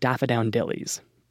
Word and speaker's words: Daffodowndillies: Part 1 the duality of daffodillies. Later, I'll learn this play is actually called The Duality of Daffodowndillies. But, like, come on. Seriously expect me to nail that pronunciation on a cold read?